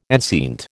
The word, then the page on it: enceinte: American English